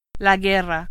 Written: guerra

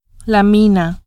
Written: mina

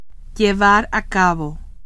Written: llevar a cabo

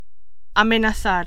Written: amenazar

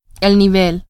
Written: nivel

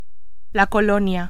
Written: colonia

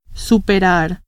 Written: superar

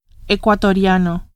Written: ecuatoriano

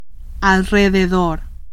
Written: alrededor

